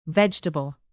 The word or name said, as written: vegetable